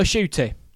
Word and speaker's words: Ncuti: So Ncuti, I've said his name correctly this time.